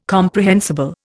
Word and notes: comprehensible: Indian

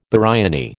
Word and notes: biryani: American English

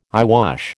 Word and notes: eyewash: American English

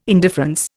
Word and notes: indifference: Indian

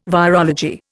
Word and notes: virology: Indian

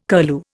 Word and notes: curlew: Indian

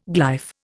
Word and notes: glyph: Indian

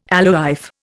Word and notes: alewife: Indian

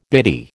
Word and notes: biddy: American English

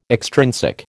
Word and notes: extrinsic: American English

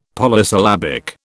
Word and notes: Polysyllabic: British English

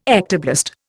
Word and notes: ectoblast: Indian

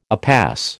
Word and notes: upas: American English

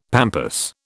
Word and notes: pampas: British English

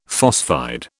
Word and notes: phosphide: British English

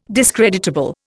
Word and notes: discreditable: Indian

